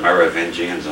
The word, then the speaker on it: Merovingians: I will not even make an effort at it. The English call them Merovingians, and I can pronounce that, so they're Merovingians for tonight.